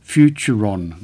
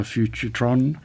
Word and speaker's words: Futuron: I beg your pardon, that Kia is called a Futuron, not a Futurtron.